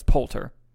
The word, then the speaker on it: Poulter: Will Poulter, or Poulter, however you pronounce it, it's Poulter.